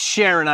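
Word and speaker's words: Charon: It's Sharon, I believe, is the common pronunciation.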